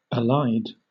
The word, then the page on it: allied: British English